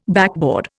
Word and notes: backboard: Indian

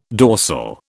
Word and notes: dorsal: British English

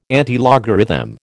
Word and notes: antilogarithm: American English

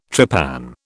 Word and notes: trepan: British English

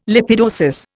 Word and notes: lipidosis: Indian

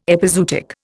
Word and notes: epizootic: Indian

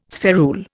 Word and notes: spherule: Indian

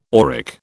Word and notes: auric: British English

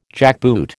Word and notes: jackboot: American English

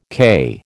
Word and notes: cay: American English

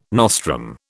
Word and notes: nostrum: British English

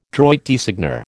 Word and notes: droit de seigneur: American English

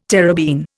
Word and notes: terebene: Indian

